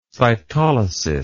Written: cytolysis

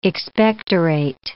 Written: expectorate

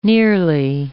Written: nearly

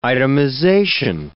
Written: itemization

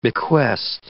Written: bequest

